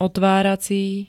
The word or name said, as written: otvárací